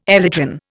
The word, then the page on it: elytron: Indian